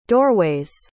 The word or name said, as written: doorway's